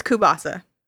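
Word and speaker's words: kielbasa: Why are you saying kubasa? – How is it pronounced? Is it not kubasa? oh no, it's the tzatziki tatziki thing all over again. – Oh my god. I'm not. It's kubasa. That's how you say it.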